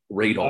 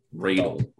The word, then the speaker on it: Raydal: Raydal. I rolled a 14. Raydal? – Raydal. Raydal. – Raydal. How do you spell it? Ray and D A L.